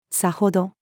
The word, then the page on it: 然程: female